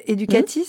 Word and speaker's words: Éducatis: Alors, vous êtes professeure certifiée de lettres modernes, créatrice d'une plateforme de podcast éducatif, Clapotis, et du site Éducatis, c'est bien comme ça qu'on prononce ? – Exactement, exactement. C'est alors, bien I à la fin.